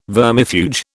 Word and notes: vermifuge: British English

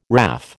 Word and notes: WRAF: American English

